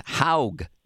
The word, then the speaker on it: Haug: Well, I just got an email back from the golfing man in Vermont, and his name is actually pronounced Haug. It's Jim Haug. Yeah, H-A-U-G.